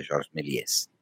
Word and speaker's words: Méliès: io ho parlato di Méliès parla- pronunciandolo così, e, e, lì ho scoperto dopo anni che lo pronuncio male, che in realtà si dovrebbe far sentire la S alla fine, quindi è Georges Méliès.